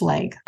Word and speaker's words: Schlage: Schlage, Schlag. Nobody knows really how to pronounce it. – I looked it up. It's Schlage.